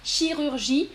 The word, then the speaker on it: chirurgie: Assoul, je répète la prononciation de chirurgie. Attention, je t'écris chirurgie. Regarde l'orthographe. Chirurgie, et la personne qui travaille, c'est un chirurgien.